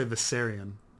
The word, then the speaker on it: Viserion: Apparently, at some point, Dany comes to help out, and Viseron? Am I saying that right? – You can pronounce it any way you want. Some people say Viserion.